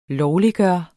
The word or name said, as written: LOVLIGGJORT